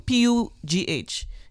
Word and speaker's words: Pugh: Pugh, P U G H is her last name.